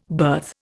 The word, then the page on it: berth: Indian